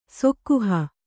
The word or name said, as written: ಸೊಕ್ಕುಹ